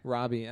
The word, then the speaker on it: Robbie: How do you say her last name? – Robbie. – Robbie, okay. Yeah, she was great.